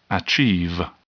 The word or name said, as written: achieve